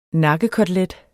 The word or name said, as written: NAKKEKOTELET